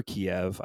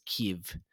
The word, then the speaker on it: Kyiv: Kiev is how it was referred to under the Soviet Union. – Post-Soviet breakup. – Got it. – The Ukrainians referred to it as Kyiv.